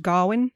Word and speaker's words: Gawain: The Christmas Game was the first one, and that's when Gawain Garwin, I'm going to say Gawain because that's how we say it in modern. – Well, yeah. Apparently it's kind of Gawain now, but back then it would have been pronounced Garwin.